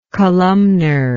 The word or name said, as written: columnarity